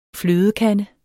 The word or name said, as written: flødekande